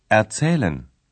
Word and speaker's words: erzählen: При някои глаголи представката е неделима. При изговарянето тя не е под ударение. Такива са, например, представките B и R. – Besuchen erzählen. – Глаголите безухен и ерцелен образуват миналото време, перфект, с помагателния глагол haben и своето минало причастие.